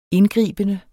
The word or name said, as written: indgribende